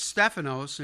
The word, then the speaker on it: Stephanos: Some say Stephen or Stephan, or really it's Stephanos in the Greek. It's pronounced that way, and it means crown.